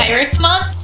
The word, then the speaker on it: Pyracmon: Pyracmon? – Pyracmon? – Well, it's got no etymology, so it's just Pyracmon.